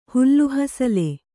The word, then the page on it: ಹುಲ್ಲುಹಸಲೆ: kannada; hullu hasale